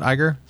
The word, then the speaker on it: Iger: Is that how you pronounce that? Iger? – Mm-hmm.